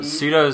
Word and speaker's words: pseudo: Now, pseudo is kind of spelled a little bit funny. – Well, that's how pseudo is spelled. That's how the word pseudo is spelled. – In general, it's spelled funny. Because the S, but it's actually spelled P-S-E-U-D-O, superheroes.